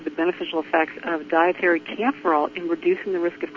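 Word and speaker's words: kaempferol: The other compound in calendula flowers that we have not yet talked about is camphorol.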